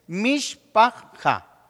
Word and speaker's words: mishpaja: Es decir, podemos pronunciarlo como está escrito. Es mishpaja.